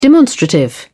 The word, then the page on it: demonstrative: British English